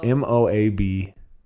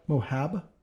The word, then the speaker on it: Moab: How's M O A B. – Oh, oh Moab. – Utah. What? Moab?